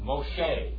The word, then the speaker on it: Moshe: And what does Moses, how do you say that in Hebrew? Moshe. What does Moshe mean?